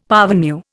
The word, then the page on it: parvenu: Indian